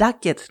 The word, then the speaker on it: Ducat: Ο supervisor ήταν κύριο James Duckett, νομίζω προφέρεται. Γράφεται Ducat, αλλά είναι, πρέπει να είναι Duckett.